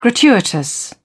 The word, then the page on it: gratuitous: British English